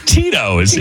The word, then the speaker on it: Tito's: And in the email, it had the pronunciation of Tito's, in case I didn't know. – Oh, T-E-E. Dash T-O-O-S. – Uh-huh. – Tito's.